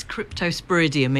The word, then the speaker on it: Cryptosporidium: It's Cryptosporidium.